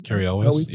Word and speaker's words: Cary Elwes: I knew Cary Elwes. How do you say that? Is that how you say it? – Cary Elwes. Yeah.